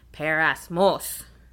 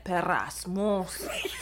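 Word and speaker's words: peirasmos: No. It's parasmos. – You gotta roll the R. – You gotta say it with that. – Parasmos.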